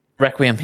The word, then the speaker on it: Requiem: how do you say this? It's a Plague Tale Requiem? – Requiem, yeah. – Yeah? – Yeah.